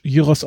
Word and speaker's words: Gyros: Und aus Griechenland, also Shawarma ist dann mit Rindfleisch und aus Griechenland gibt es dann den Gyros, oder wir kennen als Gyros, aber neugriechisch Gyros ausgesprochen, mit Schweinefleisch.